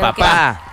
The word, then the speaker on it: Papá: Papá por Siempre es con acento en la A, no Papá por Siempre. – No, es okay. – Papá.